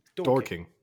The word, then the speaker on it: Dorking: Is it Dorking or Dorking? – They sound the same. – Yeah. Dorking. – Dorking. D-O-R-K-I-N-G. – Okay. – Dorking. – But not like Dorking. – Yeah, yeah. Dorking.